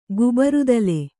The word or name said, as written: ಗುಬರುದಲೆ